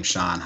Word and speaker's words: Shon: So if you just search my name, Sean Heineman, my name is spelled S-H-O-N, Heineman, H-Y-N-E-M-A-N.